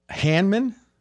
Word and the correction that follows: Hardman: Is that how you say his name? – McCall Hardman. Hardman. – Oh, I looked. That's an R. My writing could use some improvement. Hardman.